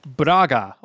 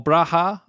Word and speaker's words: Braga: Braga, or Braha. I think the Portuguese pronounce a G, do they? Braga.